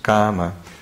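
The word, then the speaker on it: kāma: So it's kama, but it's a K long A-M-A.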